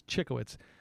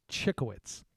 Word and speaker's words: Cichowicz: So let me just spell it for you first. So it's Chickowitz. Capital C-I-C-H-O-W-I-C-Z. So that's Chickowitz, right? Now, why I know it starts with a it sounds like it could be C-H-I-C. It's not C-I-C-H. Just get it right now. C-I-C-H-O-W-I-C-Z.